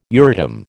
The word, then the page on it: Euratom: American English